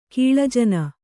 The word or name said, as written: ಕೀಳುಜನ